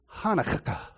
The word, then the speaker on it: Hanukkah: So the kaf with a dagish in classic Hebrew really did sound like two K's pronounced in quick succession. So it's Hanukkah.